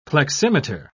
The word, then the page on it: pleximeter: British English